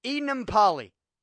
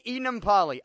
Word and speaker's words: Enemkpali: Got knocked out by linebacker, and I think he's reserve linebacker, IK Enemkpali. That's how I heard them pronounce it on TV. So I had to write it down with the pronunciation. IK Enemkpali.